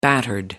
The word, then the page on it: battered: American English